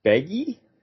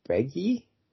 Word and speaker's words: Beghe: The lead guy who plays Alan Mann, our hero in this film, his name is Jason Begge? Is that how you say it? – Sure. – B-E-G-H-E? Begge?